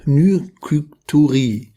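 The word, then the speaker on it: Nykturie: Ja, es geht um Nyktyurie. Also, das wird unterschiedlich ausgesprochen, aber Nyktyuri. Nyktyurie.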